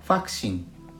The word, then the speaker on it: vaksin: インドネシア語では V の発音は F に置き換わりますなのでワクチンはファクシン